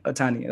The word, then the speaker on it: Ohtani: Like Ohtani, am I saying that correctly? – Yeah, Shohei.